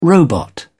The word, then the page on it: robot: British English